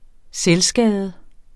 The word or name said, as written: selvskade